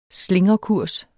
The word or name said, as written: slingrekurs